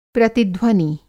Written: प्रतिध्वनि

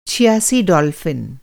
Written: छियासी

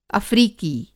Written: अफ़्रीकी

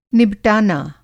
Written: निबटाना

